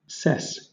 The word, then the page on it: cess: British English